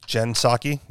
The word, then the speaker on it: Psaki: Well, the biggest thing that happened that I saw was, how do you say her name, Psaki? – Jen Psaki, yeah.